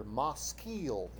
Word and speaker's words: maskil: Looks like maskil, but if you want to be a good Hebrew speaker, maskil is how you say it. Maskil.